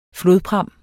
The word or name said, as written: flodpram